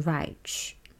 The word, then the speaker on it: Reich: 是美国的极简主义作曲家 Steve Reich，S T E V E R E I C H。也有人会念 Reich，不过我以前在美国求学的时候，大部分的人是念 Reich。嗯，那既然他是美国作曲家的话，美国人怎么念我们就怎么念吧。以下我会简称他为 Reich。